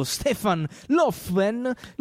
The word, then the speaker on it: Stefan Löfven: Adesso sembra ci sia una tardiva e clamorosa autocritica del premier svedese, il socialdemocratico Stefan Löfven. Giusto, l'ho detto bene? – L'ha detto molto bene, complimenti.